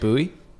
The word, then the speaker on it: Bouey: Do you see how my last name's spelled? Because it's not boy. – But you pronounce it Bouey? – It's spelled B-O-U-E-Y, so yes, so it is Bouey.